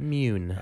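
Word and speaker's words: Muun: One of the species represented there was a moon or a mun. I'm not sure how you say it. – Mune.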